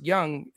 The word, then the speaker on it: Jung: I mean, you can get – I like Josh – Young, the J-U-N-G, but it's pronounced Young, is only 2.2K and got a bunch of pop.